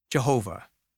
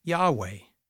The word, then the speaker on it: Yahweh: Hebrew scholars today think that the name was probably pronounced Yahweh. We know the first part because of the Yah from Hallelujah, which means praise Yah, but we don't know for sure how to say his full name.